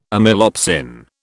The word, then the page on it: amylopsin: British English